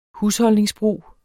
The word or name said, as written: husholdningsbrug